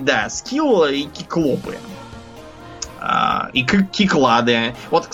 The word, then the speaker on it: киклопы: Вот, например, правильно говорить киклопы, а не циклопы.